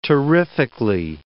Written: terrifically

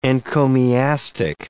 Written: encomiastic

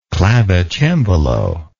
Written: clavicembalo

